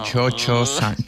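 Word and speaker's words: Cio-Cio-San: Pero como claro, la pronunciación italiana como Chocho-san.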